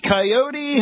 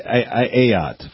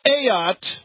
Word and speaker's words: Ayotte: Coyote? How do you say her name? – Kelly Ayotte from New Hampshire. She's a good lady. – It's close enough. – Yeah. – Kelly Ayotte.